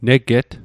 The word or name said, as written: neget